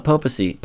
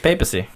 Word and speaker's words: papacy: We don't know much about the popacy. – Papacy.